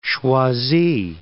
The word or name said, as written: Choisy